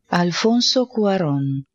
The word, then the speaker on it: Alfonso Cuarón: Alfonso Cuarón. – Alfonso Cuarón. – I feel like you're taking a Spanish course right now. – Alfonso Cuarón.